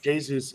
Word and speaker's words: Jesus: Jesus is Brazilian, and the Portuguese speaking people pronounce it Jesus.